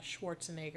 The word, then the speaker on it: Schwarzenegger: So we're introduced to Mr. Freeze, which is played by Arnold Schwarzenegger.